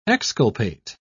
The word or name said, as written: exculpate